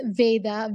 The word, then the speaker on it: Veda: It's Veda, V-E-D-A, Baldota.